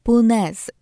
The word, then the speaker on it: punaise: Jak czytamy w słowniku wyrazów kłopotliwych Mirosława Bańki i Marii Krajewskiej, francuskie słowo określające pineskę wymawiane jest w tym języku z użyciem głoski z. Brzmi ono punaise.